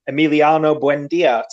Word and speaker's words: Emiliano Buendia: So, Duncan, you're still chasing me down in second, and you are going for Emiliano Buendia today. – Lovely pronunciation.